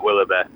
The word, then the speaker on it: Willoughby: at Matt Willoughby. – As in Willoughby, go and spell that one for us. – W-I-L-L-O-U-G-H-B-Y.